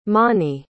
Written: monnie

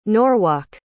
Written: norwalk

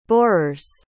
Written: borers

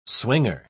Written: swinger

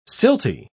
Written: silty

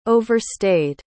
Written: overstayed

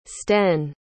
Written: sten

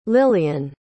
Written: lilyan

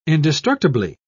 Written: indestructibly

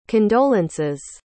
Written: condolence's

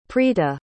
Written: prita

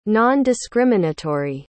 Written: non-discriminatory

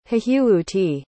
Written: hehewuti

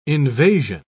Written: invasion

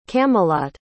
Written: camelot

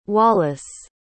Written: Wallace

